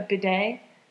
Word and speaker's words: bidet: Just so a, bid- if, a bidet. – Yeah, that's how you say it.